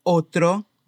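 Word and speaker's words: otro: A palavra outro não tem o U como em português.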